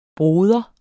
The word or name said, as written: bror